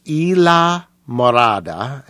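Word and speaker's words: Islamorada: It's pronounced Ila Morada, and it's named after a Florida key.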